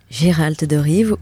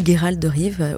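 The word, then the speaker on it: Geralt de Riv: Donc c'est un contenu téléchargeable et vous êtes encore dans la peau de Geralt de Rive ou Geralt de Rive, au choix, vous pouvez prononcer les deux.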